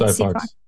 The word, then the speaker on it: SiPhox: Is that how you pronounce it? SiPhox.